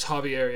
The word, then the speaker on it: Javier: With J-A-R-V-I-E-R? – That's Javier, yeah. – All right, Javier. – J makes an H in Spanish.